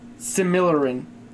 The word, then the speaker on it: Silmarillion: Silmarillion. – Silmarillion. – It's a really weird word. I'm probably mispronouncing it, to be totally honest. – Right. – It's like million, but S-I-M, Silmarillion.